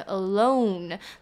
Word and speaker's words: alone: alone 呢讲单独的这个字 O 会发 O 的音，那嘴巴有一个往内收的感觉 alone，那讲 along 沿着嘴巴会上下张开发 R 的音 along。